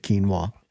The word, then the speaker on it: quinoa: You lost me at quinoa. – One of my favorite words. You don't even say it right.